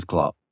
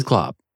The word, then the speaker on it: Cl0p: It is Klop. It is Klop. But it's spelled CL0P.